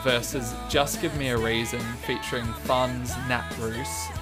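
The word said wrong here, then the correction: Nate Ruess: from, I don't know what the album was. – Nate Roos. Please say his name with some respect. – Nate Roos, sorry.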